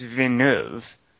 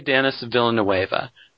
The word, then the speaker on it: Villeneuve: And the last name is just like two syllables. I think it's Veneuve.